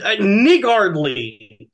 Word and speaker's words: niggardly: I said niggardly, Thank you. That's how you say it.